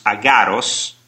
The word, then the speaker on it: Agares: But basically, when you conjure a demon, like Agares, pronounced Agaros, to produce an earthquake, somebody which I know gave me these final results of these experiments.